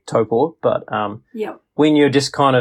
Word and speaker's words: Taupo: But it's same with Taupo. I think it's just become the norm for non-New Zealanders to pronounce it incorrectly. – Yeah. But even us down south, we'll say Taupo. But it's really? – It's Taupo. – Yeah. Oh, shivers. So do you get frowned upon being a New Zealander and still saying it incorrectly? – Well, most New Zealanders probably say it incorrectly, so it depends.